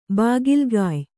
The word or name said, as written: ಬಾಗಿಲ್ಗಾಯ್